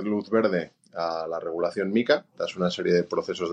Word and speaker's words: MiCA: El otro día nos reíamos porque la gente discute sobre la pronunciación de si Maica y Mica. Bueno, ya la comisión ha dicho que es Mica, o sea, que cuando alguien diga Maica, pues que sepa que se muere un gatito, que hay que decir Mica.